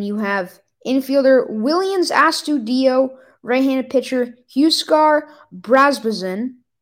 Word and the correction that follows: Brazoban: Brazoban, my bad.